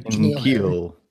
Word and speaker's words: N'Keal: A lot of love for, I'm going to pronounce his name wrong, Keneal Harry? – N'Keal. – What is it? – N'Keal. – N'Keal? I switched the N and the K.